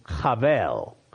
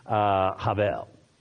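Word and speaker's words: chavel (הבל): The Hebrew word is, and the way it would be pronounced in Hebrew actually, is chavel. Chavel. There's almost that H, that guttural H sound at the beginning of it. And probably the Hebrew would pronounce it more of a V sound than a B sound.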